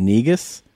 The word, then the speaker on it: negus: The word is spelled, by the way, N-E-G-U-S. And even though the guy who's in charge of the spelling bee is saying negus, according to this video, it's correctly pronounced negus. So he was mispronouncing it.